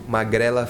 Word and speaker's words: Magrela: Magrelella Fever? – Yeah. – How do you say that? – Magrela Fever. – Oh, pretty close.